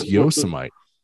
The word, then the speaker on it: Yosemite: I was like, where is Yosemite? He's like, it's out near California. I was like, you mean Yosemite? He's like, is that how you pronounce it? I thought it was Yosemite.